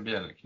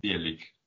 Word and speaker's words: Bielik: Bielik. – Bielik. – Yeah, the stress would be on the first syllable, I think.